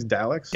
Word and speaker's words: Dalek: Daleks? – Dalek.